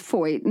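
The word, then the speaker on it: Feucht: It's pronounced Foyt, and of course, his first name has to be the Sean that's spelled like Sean, but, you know.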